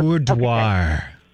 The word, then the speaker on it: boudoir: I don't, boudoir.